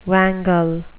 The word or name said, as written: wangle